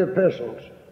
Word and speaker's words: epistle: Just like the three letters that John wrote, that in the Bible referred to as the three epistles of John. The T is silent and it's epistle as apostle, not apostle nor epistle.